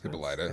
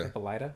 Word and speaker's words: Hippolyta: Because, I think like the Hippolyta episode, it's Hippolyta, right? Is that how it's pronounced? Hippolyta. – Hippolyta? Okay. – Yeah, Hippolyta.